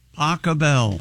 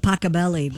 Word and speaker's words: Pachelbel: Johann, is it? – Pachelbel. – Pachelbel? – No. – It's Pachelher. – I've always said Pachelbelly. – Pachelbel. – Birthday. – Pachelbel's canon.